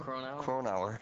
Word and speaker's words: Cronauer: Cronauer.